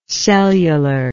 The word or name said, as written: cellularly